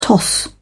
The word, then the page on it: toss-up: British English